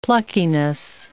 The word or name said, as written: pluckiness